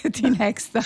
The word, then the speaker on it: Tinexta: Perché ero indecisa se pronunciarlo all'inglese Tinexta oppure Tinexta. – No, è Tinexta, va bene.